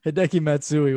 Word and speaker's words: Hideki Matsui: Hideki Matsui was a former Yankee. – Oh, yeah. I was just saying, no, I said that right, didn't I? Yeah, yeah, okay, no, you did. Yeah.